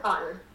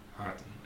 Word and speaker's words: Haughton: Did I pronounce that right, Candice? – You sure did. Candice Haughton. – Haughton, okay.